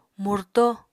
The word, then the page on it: murto: Apatani